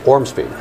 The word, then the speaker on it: Ormsby: And, uh, and, Brian, for some reason, just stumbled on my last name, which is not difficult. It's six letters, and you pronounce every one in order. Say it for us. Ormsby.